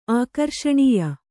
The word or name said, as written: ಆಕರ್ಷಣೀಯ